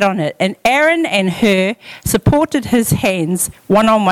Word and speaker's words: Hur: And Moses, Aaron, and Hur, how do you say this? Hur or Ur? Hur.